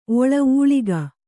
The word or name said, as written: ಒಳ ಊಳಿಗ